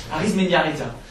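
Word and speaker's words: Arizmendiarrieta: Arismendiarrieta. – Le basque, ça se prononce comme ça, c'est écrit.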